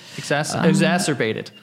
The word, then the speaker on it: exacerbated: It just fucking exasperated the entire thing. Did I say that right? Exasperated? Exacerbated. – Yeah. There we go.